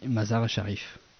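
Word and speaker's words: Mazar-e Sharif: Alors nous sommes en 2012, tu pars d'Afghanistan, euh, tu es originaire du nord de l'Afghanistan, alors je te laisse dire le nom du village parce que j'ai peur de l'écorcher. oui, Mazar Sharif. – Mazara Sharif. – Mazar Sharif, ouais.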